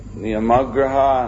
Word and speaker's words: Niyamagraha: Niyamagraha and Agraha, the long A and the short A.